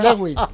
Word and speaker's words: Lewin: Lewin.